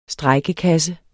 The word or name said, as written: strejkekasse